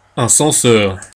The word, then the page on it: censeur: Paris; [sɑ̃.sœʁ]